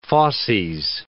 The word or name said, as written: fauces